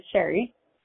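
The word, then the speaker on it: Sherrie: I have one person I work with on the regular that's always spelling my name is Sherry. It's S-H-E-R-R-I-E. They spell it S-H-E-R-R-Y constantly.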